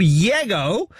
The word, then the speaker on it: Llego: It's called Yego, even though it's spelled L-L-E-G-O. It could have been Lego. I mean, come on.